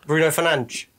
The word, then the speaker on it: Bruno Fernandes: Bruno Fernandes.